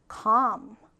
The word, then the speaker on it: calm: 자, 발음해 볼까요? calm. calm. 그쵸? L을 발음하지 않아요. 그냥 calm이라고 합니다.